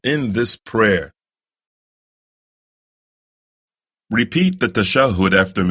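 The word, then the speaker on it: tashahhud: As for the 10th pillar of salah, is the recitation of the final tashahud. Remember, it's not tashahud. It's pronounced tashahud.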